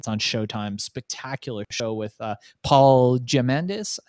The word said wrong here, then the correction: Giamatti: I think you pronounce it. – Giamatti.